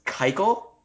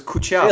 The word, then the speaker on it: Keuchel: Did you know his name is actually Dallas Keuchel? Can you believe that's Keuchel? I actually thought it was Kuchel.